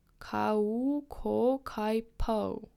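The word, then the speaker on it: kaukokaipuu: Probably pronounce this word, Kauko Kaipu. I would guess. Oh, pronounced Kauko Kauko Kaipo. Kauko Kaipo. Kauko Kaipo. Okay, I got it, I think.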